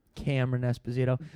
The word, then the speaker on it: Cameron Esposito: Rhea Butcher and Carmen Esposito. – Cameron Esposito. But it's good for you to mispronounce a white name.